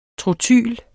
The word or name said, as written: trotyl